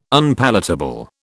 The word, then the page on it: Unpalatable: British English